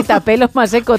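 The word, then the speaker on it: Thapelo Maseko: ¡Qué bien lo has dicho! No sé si se dirá así. – Sí, Tapelo Maseko, tal vamos. Cual. Lo he leído tal cual se escribe.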